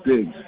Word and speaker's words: Diggs: Jim Diggs. – You said Jim D? – Diggs, D-I-G-G-S. – D-I-G-G-F?